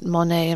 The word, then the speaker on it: Monet: And so we had friends living in Rhodesia, and one of them said to us, have you heard about Zimbabwe's secret Monet, as in the artist Monet? I'm not mispronouncing money.